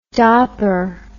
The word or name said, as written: dopper